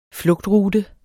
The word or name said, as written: flugtrute